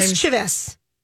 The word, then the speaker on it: mischievous: Mischievous is how you're supposed to say it. Mischievous, not mischievous.